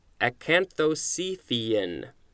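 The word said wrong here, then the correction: acanthocephalan: A-C-A-N-T-H-O-C-E-P-H-A. Oh, that's an L. L-A-N. Acanthocephalin. And I'm just going to check the pronunciation. Acanthocephalin. Yep.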